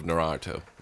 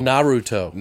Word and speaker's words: Naruto: I actually really love Naruto. Or Naruto. – Naruto. – Naruto. – Say it right. – Naruto. – Say it fucking right!